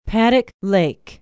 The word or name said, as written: paddock lake